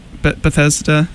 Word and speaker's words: Bethesda: with all the major corporations of gaming, Microsoft, Sony, EA, Bethesda.